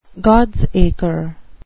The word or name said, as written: gods acre